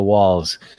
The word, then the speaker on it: Walz: Is it the well, I don't know how you pronounce it, like Wals, W-A-L-Z? – Yes, it is. Yeah. Thank you very much, Simon. That it is the Walls, which has Zuno lenses on it.